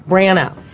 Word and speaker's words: Branagh: How do you pronounce his name? – Branagh.